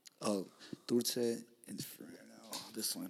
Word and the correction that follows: Dulce Infierno: Mezcal Dulce Infierno.